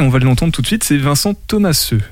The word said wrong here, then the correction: Thomaset: C'est. Vincent Thomaset. Vincent Thomaset.